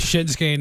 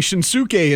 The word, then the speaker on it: Shinsuke: Yeah, it took me a long time of reading Shinsuke in my head before I ever heard it said and realized, you don't pronounce that ooh like an Asuka.